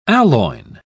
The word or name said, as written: aloin